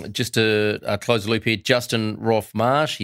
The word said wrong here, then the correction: Roff-Marsh: his surname is Roff-Marsh.